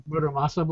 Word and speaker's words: Muramasa: But it's very similar to uh, Marasima Blade. Okay. – Mm-hmm. Um, Muramasa blade. Yeah, I mispronounced it.